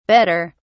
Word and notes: bedder: British English

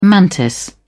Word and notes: mantis: British English

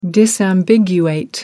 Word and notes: disambiguate: American English

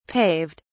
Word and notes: paved: British English

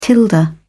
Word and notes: tilde: British English

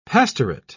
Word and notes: pastorate: British English

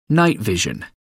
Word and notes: night vision: American English

